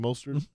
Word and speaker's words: Mostert: Mostert? – Yeah.